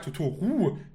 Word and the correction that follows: Totoro: der heißt Totoro.